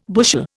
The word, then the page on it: bushel: Indian